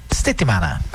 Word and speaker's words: settimana: É semana isso a pronúncia? – Settimana. – Hã? – Settimana. – Settimana.